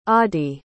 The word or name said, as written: Aadi